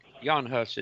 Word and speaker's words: Jan Hus: Yes, Jan Hus. It's J-E-N, pronounced Jan Hus.